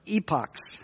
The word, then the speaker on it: epochs: Is that epics or epochs? How do you pronounce that word? Epochs? That's what I thought.